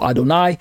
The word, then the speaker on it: Adonai: So on the right side of your screen, that's God, pronounced as Adon plus the word I, so Adonai.